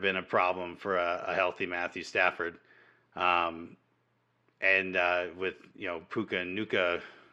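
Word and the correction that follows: Puka Nacua: Puka Nakua, yeah.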